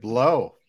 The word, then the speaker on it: Blough: David Blough. That's how you pronounce it.